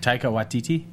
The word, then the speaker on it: Taika Waititi: Taika Waititi? – Yes.